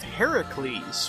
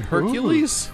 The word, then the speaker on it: Heracles: So Heracles is the original like Greek pronunciation and spelling of it. Hercules is the Romanized version that most people know.